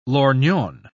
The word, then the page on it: lorgnon: British English